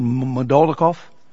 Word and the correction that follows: Moldakhmedov: Moldakhmedov. – Moldakhmedov, yes.